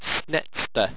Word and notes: snetsta: owstwecian

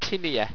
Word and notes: tinea: owstwecian

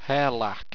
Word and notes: hirrlaq: Owstwecian

